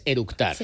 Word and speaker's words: eructar: y lo primero que hizo es orutar. – Con orutar es eructar.